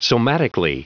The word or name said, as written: somatically